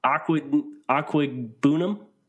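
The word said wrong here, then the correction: Okwuegbunam: It's Okuwebunam. Okuwebunam.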